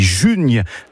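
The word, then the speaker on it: junh: Et on s'en déjà le mes des junes, qu'aime déjà au mes des junes. Déjà juin, effectivement. Juin qui se prononce en occitan june. Ou june.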